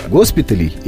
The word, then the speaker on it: госпиталей: Например, госпиталей или госпиталей, да, вот э, родительный падеж множественного числа, он как правильно произносится? – А как вы думаете, какой вариант вы бы выбрали? – Ну, госпиталей оно половнее лучше ложится на язык, но что-то мне подсказывает, что все-таки госпиталей оно правильнее. Это ударение никуда не должно переходить, по моим ощущениям.